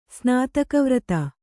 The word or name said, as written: ಸ್ನಾತಕವ್ರತ